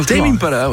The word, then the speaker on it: Tame Impala: C'est sur Tame Impala, je le prononce correctement. – Tame Impala, ouais, Tame Impala. C'est ça.